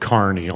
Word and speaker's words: Kearney: It's a primarily undergraduate teaching campus at a place called Kearney, although it looks like Kearney. – Okay, so that's spelled K-E-A-R-N-E-Y.